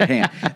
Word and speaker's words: Han: Han.